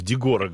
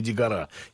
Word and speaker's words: Дегора: Ведь знаете, что я неправильно ставлю ударение, в Дегора говорю, а не Дегора.